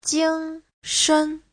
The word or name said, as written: jing1shen5